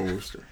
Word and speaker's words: Worcester: They made the first one for the, I'm going to try to say this right, the Worcester. – Oh, Worcester.